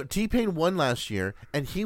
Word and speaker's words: T-Pain: T-Pain. – T-Pain? – Pain, uh-huh. I said it right. – As in, ouch.